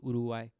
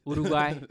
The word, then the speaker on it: Uruguay: And then recently I saw this video of a tornado pass through Uruguay. It's crazy, man. – Where is that? I don't know what the fuck that is. – Uruguay. It's right below Argentina. – Okay. – Uruguay.